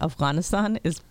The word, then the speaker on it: Afghanistan: She asked Joe Biden a question about Afghanistan, which is the way we pronounce it, and it's correct. Anyway, she pronounced it correctly.